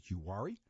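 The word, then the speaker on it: Uwharrie: That's U-W-H-A-R-R-I-E. I pronounce it Yuhari.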